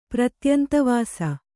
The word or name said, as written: ಪ್ರತ್ಯಂತವಾಸಿ